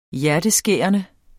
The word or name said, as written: hjerteskærende